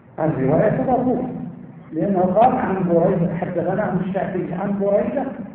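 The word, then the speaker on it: بُرَيدة: بريدة، هكذا ينطق بفتح ضم أوله وفتح ثانيه،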